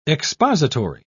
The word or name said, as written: expository